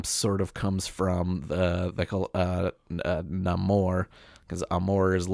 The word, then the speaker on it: Namor: I saw a clip where he's talking to the queen, and he says, Namor. – Yeah. – So I guess that's how you pronounce it.